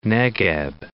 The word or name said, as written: Negeb